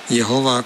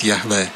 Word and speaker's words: Yahweh: Numele Iehova, cum l-a pronunțat telespectatoarea noastră, este o formă, să spunem așa, mai veche și deformată, are o vechime de câteva secole, a numelui sacru al lui Dumnezeu, care se pronunța de fapt Yahweh.